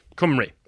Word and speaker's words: Cymru: Plaid Cymru? – Cymru. Yeah, it doesn't look like it's pronounced that, but I have been corrected on this myself.